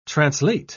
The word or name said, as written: translate